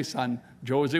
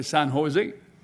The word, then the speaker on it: San Jose: Oh, he said, "Ma'am, in California, the J, we pronounce it like an H. We don't say San Jose, we say San Jose.